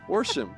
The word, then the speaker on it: Worsham: Special thanks to our guest today, Dr. Chris Worsham. – And Dr. Who what now? – Dr. Chris Worsham. Worsham.